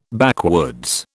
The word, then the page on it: backwoods: British English